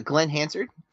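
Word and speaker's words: Glen Hansard: Glenn Hansard and Markita Erglova.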